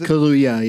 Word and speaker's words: Kaluuya: Is that how you say his name? – Yeah. – All right, because that's also the name of a drink, right? Maybe I got it wrong. – Then you did get it wrong. It's Kaluuya, yeah.